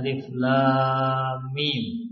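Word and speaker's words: alif, lam, mim (الم): Alif lam mim. Nah, dibuka dengan tiga huruf. Alif, lam, kemudian mim. Nam. Ini alif, bacanya alif, lam, mim. Bukan bacanya alam.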